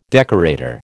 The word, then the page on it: decorator: American English